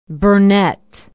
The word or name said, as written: burnett